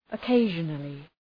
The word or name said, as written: occasionally